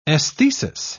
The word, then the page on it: esthesis: British English